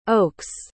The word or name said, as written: Oakes